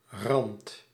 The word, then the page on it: edge: /ɛdʒ/